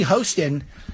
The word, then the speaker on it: Hostin: Here's Sunny Hostin. I'm told you're supposed to be pronounced Hostin, but I'm just saying Hostin because she's hosting a show called The View.